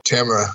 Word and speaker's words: Tamara: We have an interview with film director and author and editor of country music magazine, Tamara Saviano, although I believe in America it's pronounced Tamara. I'm not sure. – Tamara, yep.